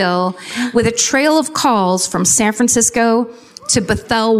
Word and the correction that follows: Bothell: Washington. I don't know if that's how it's pronounced. Bothell, I said. Like Bothell, Washington.